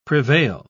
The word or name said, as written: prevail